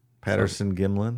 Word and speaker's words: Patterson Gimlin: The Gimlet Patterson? – Patterson Gimlin. – Patterson Gimlin video. We got a lot of shit for that one. – We get shit for saying the name wrong.